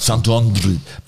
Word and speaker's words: Santo André: Darf man das so aussprechen? Weiß ich gar nicht. Ja, darf Santo André, wie auch immer.